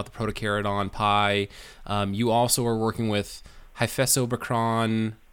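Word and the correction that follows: Hyphessobrycon: Did I butcher it, or Hyphesobrychon? – Hyphesobrychon too.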